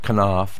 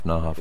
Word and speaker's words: Knopf: I don't know how to pronounce that. Knopf, Knopf.